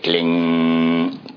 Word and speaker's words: Klim: If you were in South India, you would say Klim. If you were in North India, you say Kling. Kling. Either. So then you go more of a nasal. Kling or Klim. Either. It's spelled K-L-I-M.